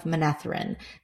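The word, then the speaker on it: Manetheren: This leads Meereen to tell the story of Manatharin. Sorry. I always say this wrong because I said it wrong in my head. Okay. This leads Meereen to tell the story of Manethrin, the ancient city where Edmund Field is.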